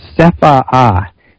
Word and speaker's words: CEFAA: So interesting enough, the group that they have that's investigating UFOs is called CEFAA, C-E-F-A-A, and they pronounce both A's, so it's A-A, which is kind of fun to say.